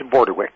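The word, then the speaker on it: Bordewich: It's Bordewick. – Bordewick. But it's spelled with a C-H. – Yes, it is. It's a Norwegian name. It's a bit tricky.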